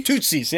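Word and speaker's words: Tutsi: The Shootsies. Well, how do you say it? Tootsie. Tootsies. Yes. Yeah.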